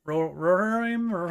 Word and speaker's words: Rohirrim: Lord of the Rings, The War of the Rohirrim. – Yeah, we might. I wonder if we're mispronouncing that. Rohirrim? – Rohirrim. – Rohirrim. Okay.